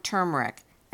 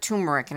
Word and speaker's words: turmeric: and 1/4 teaspoon of turmeric. And I'm saying it correctly this time, having called it turmeric in an earlier episode.